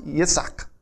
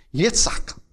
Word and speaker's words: Yitzhak: In the Hebrew, his name is pronounced Yitzhak. Yitzhak.